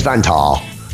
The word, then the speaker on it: Vantaa: They're from, right, it's spelled V-A-N-T-A-A, I'm calling it Vantaa, from Finland.